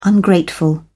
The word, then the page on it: ungrateful: British English